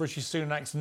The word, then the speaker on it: Rishi Sunak: Just today, we've got news that Rashid Sunak is now the Prime Minister. – Rashid Sunak. I mean, that was pretty breathtaking to get both parts of his name wrong.